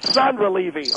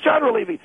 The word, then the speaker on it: Chandra Levy: It ain't Sandra Levy, okay? It's Chandra Levy.